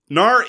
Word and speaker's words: Knarr: I, I actually make the comparison because in much the same way that the G in gnarly is silent, uh, there's a K at the the top of of Knarr, which is silent. I think it was hiding between the G and the H of through. Anyway. Knarr is, as you say, a very interesting set of competing economies, despite the fact that it is a very, very brief game.